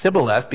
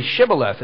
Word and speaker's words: Shibboleth: If they could actually pronounce a word correct, that word Shibboleth, pronounced by the Ephraimites as Sibboleth, because their dialect had been corrupted. See, the pure Hebrew is actually Shibboleth.